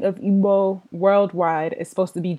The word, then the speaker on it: Igbo: All right, the first word is Igbo. – Igbo.